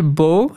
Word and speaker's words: beau: Zoals bijvoorbeeld het woord voor mooi, beau in het Frans. Mhm, mhm. – Dat schrijf je met EAU. E ja. EAU omdat dat in de 14e eeuw ook echt in Frankrijk als Beau werd uitgesproken.